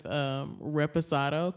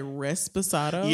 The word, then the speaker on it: reposado: Like Brittany last night, I had to help her with reposado, because a lot of people say resposado. – Yeah, reposado.